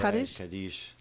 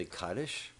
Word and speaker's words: Kaddish: Right, Kaddish. – Kaddish. – That's sometimes people say Kaddish. – Mm-hmm. – Like Ashkenazi pronunciation is more Kaddish, and everyone else usually says Kaddish.